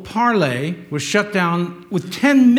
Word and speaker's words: Parler: spelled P-A-R-L-E-R, but it's French, it's pronounced Parler.